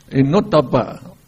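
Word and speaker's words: anottappa: And anuttapa, A-N-O-T, it's called not, anuttapa. So O is pronounced O. When you put T there, it becomes O, anuttapa, it lack of moral dread.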